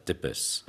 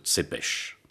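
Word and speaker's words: Țepeș: Enfin, je pense que vous le savez, Dracula, c'est Vlad Tepes. Mais en roumain, on prononce Tsepes.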